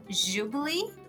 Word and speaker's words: Jubilee: E eu queria dividir, na verdade, um canal de YouTube que se chama Jubilee. Eu acho que é assim que se pronuncia. É, se escreve J-U-B-I-L-E-E.